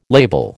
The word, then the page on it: label: American English